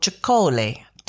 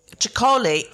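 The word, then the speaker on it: Txakoli: Chicole. Do you know it? – No. – So it's Basque spelling. It's T-X-A-K-O-L-I or something. – Yeah. – But they sometimes spell it phonetically, Chicole. So C-H-A-C-O-L-I.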